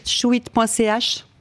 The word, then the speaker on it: Shewit: Vous pouvez tout retrouver chouit.ch. Vous très bien. – Tout. – Et ça s'écrit, ce qui n'est pas forcément évident, S-H-E-W-I-T. Merci beaucoup, d'être venu dans cette émission.